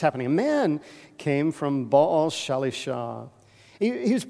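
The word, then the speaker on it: Baal Shalishah: Now, I want you to focus in on that word, Baal Shalisha.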